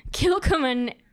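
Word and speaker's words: Kilchoman: It is Kilcoman Islay, is that how you say it? – Sure.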